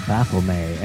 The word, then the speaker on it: Baphomet: As far as I know, Baphomet, and my wild friends from Windermere, Pennsylvania, Lucas, Don Reese, and others, Mike Corsi, James Cabetta, have all read the Satanic Bible by Antoine Sanzer-Lavey and said that that is pronounced Baphomet.